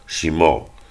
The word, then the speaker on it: Chimon: Amani nannte Simon immer in ihrem gebrochenen Deutsch mit starkem französischem Akzent Chimon, mit Betonung auf der zweiten Silbe und das O auf Französisch durch die Nase gesprochen. Ich nannte die Zwillinge inzwischen auch Chimi und Mimi.